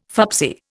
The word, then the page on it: fubsy: Indian